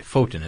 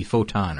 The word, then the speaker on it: Foton: And I hope I'm saying that right, Foton. It's F-O-T-O-N. It's maybe Photon or something. – Mm-hmm. – But Foton, let's say.